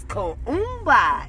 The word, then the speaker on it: Kuumba: Yes, Kuumba. And it's spelled K-U-U-M-B-A.